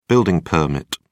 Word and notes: building permit: British English

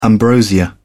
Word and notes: ambrosia: British English

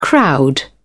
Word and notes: crowd: British English

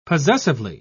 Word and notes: possessively: British English

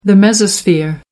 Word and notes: mesosphere: American English

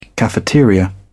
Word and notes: cafeteria: British English

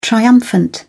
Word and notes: triumphant: British English

